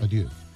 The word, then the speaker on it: Adieu: Aquest no me l'he llegit, es nota, perquè no ho he pronunciat massa bé, però igualment Adieu. És boníssim, eh? També. – Sí, Adieu.